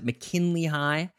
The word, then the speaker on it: McKinley High: And fans and critics have all felt the show was, well, I guess tired, with students who already graduated from McKinley High. Is that how you pronounce it? McKinley High? – Yeah, you're right.